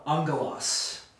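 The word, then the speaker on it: Angelos (ἄγγελος): Angelos, that's how you say it. It's an alpha, gamma, gamma, epsilon, oh my gosh, lambda, omicron, sigma.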